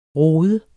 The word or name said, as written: rodet